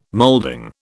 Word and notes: molding: British English

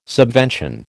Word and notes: subvention: American English

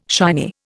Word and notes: shiny: Indian

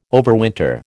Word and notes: overwinter: American English

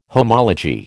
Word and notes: homology: American English

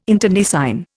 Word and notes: internecine: Indian

